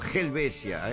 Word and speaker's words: Helvecia: Nueva Helvecia, no, el H no se pronuncia, es nuevo, ustedes no entienden, pero Helvecia, está bien, me voy una Honda, yo qué sé.